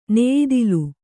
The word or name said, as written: ನೆಯಿದಲೆ